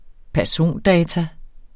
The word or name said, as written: persondata